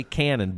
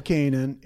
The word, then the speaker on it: Canin: Some people say canine. Some people say canon, but it's French, and yes. – it's actually Royal Canin. – Royal Canin.